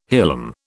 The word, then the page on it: hilum: British English